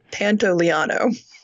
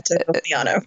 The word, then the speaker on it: Pantoliano: Pantoliano. – Wait. Oh, yeah. – Pantoliano.